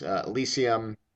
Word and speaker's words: Elysium: And then the last one is Elysium.